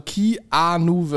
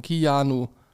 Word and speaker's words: Keanu: Kiano wird, also Keanu wird ausgesprochen. Keanu.